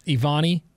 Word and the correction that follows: Evian: or how do you say it? – Evian.